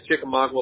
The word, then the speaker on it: Chickamauga: Is it the 101 Chickamauga? I can't pronounce that correctly, man. Lane, is that right? – Yeah, Chickamauga Lane.